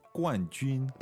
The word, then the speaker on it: 冠军: Okay, so 冠军 is fourth tone, first tone.